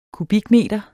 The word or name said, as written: kubikmeter